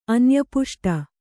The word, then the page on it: ಅನ್ಯಪುಷ್ಟ: anyapuṣṭa